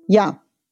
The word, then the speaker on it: я: Ja. Ja heißt ich. Ausgesprochen ja. Ich heißt auf Russisch ja ein Buchstabe nur. Der letzte im Alphabet.